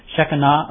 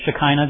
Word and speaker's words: Shekinah: They also had the glory, and as we've mentioned before, that's the Shekinah, or the Shekinah, depending on whether you want to pronounce it with more of a Jewish view or not.